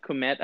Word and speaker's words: Kmet: But with tight end, I think, you know, I spoke with, chatted with Mike a little bit about Cole Kmet. Is that how you say it? Kmet, I believe. – Yeah, Cole Kmet. Yep.